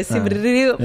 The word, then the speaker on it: Siem Reap: But no one can ever do the R, so we just go Siem Reap.